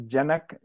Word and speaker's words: Janak: Well, I, for anybody who wasn't there previously, for your new listener, my name is Janak Joshi, and I'm running for CD8.